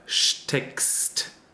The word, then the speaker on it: steckst: And within the word, or at the end of a word, it's pronounced separately, so with S-T. That's why we have steckst right here.